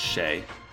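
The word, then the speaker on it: Sheh: S-H-E-H. I don't know how to pronounce it. I think it's Shay.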